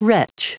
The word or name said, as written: wretch